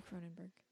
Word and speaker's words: Cronenberg: David Cronenberg plays a priest in this. Right? It's David Cronenberg? – Yeah. – Okay. I always feel like I get his name wrong.